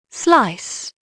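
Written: slice

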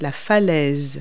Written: cliff

